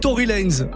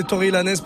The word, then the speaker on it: Tory Lanez: Euh, on peut dire Tory Lanes, mais moi je vais l'annoncer Tory Lanez parce que c'est vous les boss, c'est vous qui choisissez.